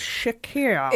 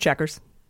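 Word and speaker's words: Chequers: So when you spelled it, I was like, yeah, I guess that's Shakira. – Exactly. Shakira. – So it's just pronounced checkers.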